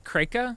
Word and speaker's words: Kraken: Apparently, like Kreka is closer to the correct pronunciation, and the N is the article.